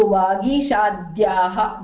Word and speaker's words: vagishadhyaha: So the half consonant of a joint consonant should be pronounced when you're reading your mantras or your shlokas, should be pronounced along with the earlier consonant or swara. Okay, so vagishadhyaha, the minute you say vagishadh, yaha, then your chanda or your prosody becomes good.